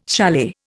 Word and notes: chalet: Indian

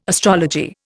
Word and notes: astrology: Indian

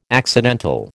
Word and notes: accidental: American English

